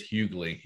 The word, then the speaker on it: Hughley: And by the way, it is Hughley. He corrected me on that. So he said, you got to pronounce the G.